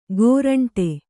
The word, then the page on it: ಗೋರಂಟೆ: gōraṇṭe